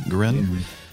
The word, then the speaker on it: Guerin: And then St. Theodora Guerin, how do you pronounce that? Guerin. That's on next Friday, and then next Saturday, I believe, is St. Francis of Assisi.